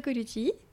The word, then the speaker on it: Colucci: Bonne écoute Aujourd'hui, je reçois Enzo Colucci.